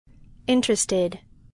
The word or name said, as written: interested